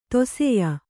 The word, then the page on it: ದಾಸೇಯ: kannada